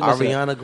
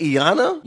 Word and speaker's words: Ariana: I always Ariana Grande. – Ariana Grande. – You said Iana? – Yeah, I said Iana.